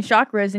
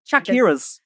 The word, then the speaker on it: chakras: She's taking you to Reiki and you're talking about your fucking chakras and you don't even know how to pronounce it properly. You're over here calling them Shakira's. – Checkers. Yeah, my checkers are out of balance.